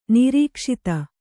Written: ನಿರೀಕ್ಷಿತ

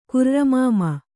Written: ಕುರ್ರಮಾಮ

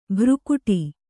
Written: ಭ್ರುಕುಟಿ

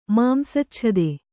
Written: ಮಾಂಸಚ್ಛದೆ

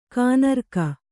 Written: ಘನರಾಗ

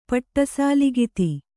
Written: ಪಟ್ಟಸಾಲಿಗಿತಿ

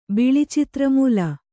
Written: ಬಿಳಿಚಿತ್ರಮೂಲ

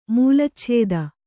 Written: ಮೂಲಚ್ಛೇದ

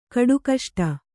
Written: ಕಡುಕಷ್ಟ

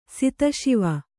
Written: ಸಿತಶಿವ

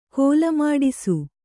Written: ಕೋಲಮಾಡಿಸು

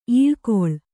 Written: ಇಲಿಗುಲಿ

